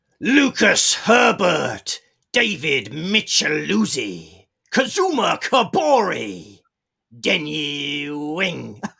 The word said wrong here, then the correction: Wen Yi Ding: Sorry, I got so excited there. I said his name wrong. Wen Yi Ding. Ding dong, Wen Yi Ding.